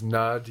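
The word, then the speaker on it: Naad: Today we want to speak about one of our favorite, if not favorite, subjects, which is Nod Yoga. – And that's N-A-A-D, Nod. – Yes, to emphasize the ah sound. We spell it with two A's.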